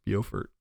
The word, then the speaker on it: Beaufort: I learned that in South Carolina, it's pronounced Beaufort.